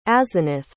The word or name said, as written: asenath